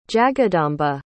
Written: jagadamba

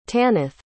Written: tanith

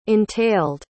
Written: entailed

